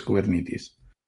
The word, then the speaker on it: Kubernetes: Yo creo, yo creo que nadie lo pronuncia bien. Si, hay que fiarse de lo que dice, de cómo lo pronuncian en las charlas y demás, es Kubernetes. Kubernetes. Sí, y según los estándares de pronunciación norteamericanos y, y de griego clásico, que es de donde viene la palabra.